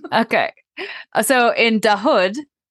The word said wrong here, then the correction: Dahod: okay, I'm sorry. I have to apologize for my Indian pronunciations. But Dahod, that's what it is. It's Dahod.